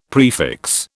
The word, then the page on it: prefix: British English